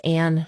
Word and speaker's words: Ann: And if you search under faculty and staff, my name is Ann Chavone, A-N-N, and the last name is spelled S-C-H-I-A-V as in Victor, O-N-E.